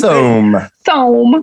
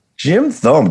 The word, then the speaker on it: Thome: But I thought it was Tomey. I put Jim Tomey. – Let's go. – Jim Tomey. – I didn't know how to pronounce Thome. – It. – Jim Thome.